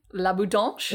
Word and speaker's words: La Boutanche: La Boutanche.